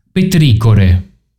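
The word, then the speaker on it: petrichor: Questa forse la conosci, io non la conoscevo, che è petricore. – No, credo che si pronunci petricor.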